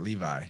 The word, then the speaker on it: Levi: And I love John is it Levi or Levi? Whatever, he's amazing. – I pronounce it Levi. – Levi. – J-O-N-L-E-V-I, 20-minute-ish videos, really bite-sized.